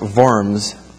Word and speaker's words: Worms: Now 2 years later, Martin Luther, at the Diet of Worms, it's got a W, but it's pronounced Worms.